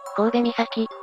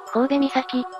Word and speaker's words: 首岬: 第 1 位は、神戸岬、だ。大船渡市、三陸町にあるぜ。首岬神戸岬、と読むんだぜ。